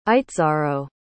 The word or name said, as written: itxaro